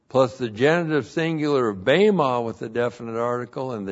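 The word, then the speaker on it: bema (βῆμα): B, long E, M, A, bema.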